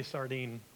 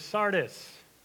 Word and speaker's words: Sardis: Every time I see that word, I want to say sardine. So if that slips out, you can laugh, that's fine. I'm going to try not to pronounce it sardine. It is Sardis.